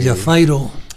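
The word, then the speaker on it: Javairô: Jafairo, en Países Bajos, Jafairo, que se escribe como grande, él fue el que nos hizo saber que se decía Jafairo.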